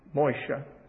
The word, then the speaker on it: Moshe: There are many Jews today named Moses, although they would pronounce it Moshe, which is to draw out.